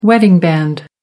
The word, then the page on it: wedding band: American English